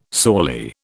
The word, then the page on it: sorely: British English